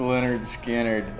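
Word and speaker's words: Lynyrd Skynyrd: Pronounced Leonard Skinner.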